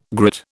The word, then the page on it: grit: British English